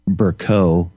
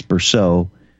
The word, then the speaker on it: Bercot: I think in that, Greg Fraser actually mentions David Berceau, which is, he pronounces Bercot, it's Berceau, but it's spelled B-E-R-C-O-T, so it's, you know, phonetically Bercot.